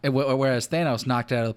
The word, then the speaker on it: Thanos: Like, the people make this movie think they're comic book fans, but they don't even pronounce his name right. Thanos.